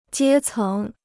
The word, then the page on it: 阶层: jiē céng